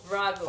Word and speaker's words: Raguel: Raguel. – R-A-G-U-E-L.